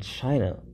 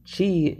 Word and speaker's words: ki: Notice I said chi and then ki. Chi is how China pronounces it, and ki is how Japan pronounces it.